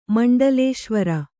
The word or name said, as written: ಮಂಡಲೇಶ್ವರ